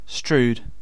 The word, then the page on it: strewed: British English